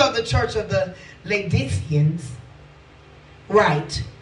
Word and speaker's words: Laodiceans: I'm going to start over because he just gave me the pronunciation. Okay, Lord. And unto the angel of the church of the Laodiceans. That's what he said. Laodiceans. Because the O is silent.